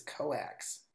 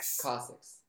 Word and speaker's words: coccyx: After General SVR's Telegram channel asserted that Putin had fallen down 5 steps on a staircase, landing on his, what is this, coax? Is that, am I even saying that right? – Is it like bone? Like it's COCCYX. – Cossacks. – Cossacks. What is that? Not Cossacks, but Cossacks. I don't know.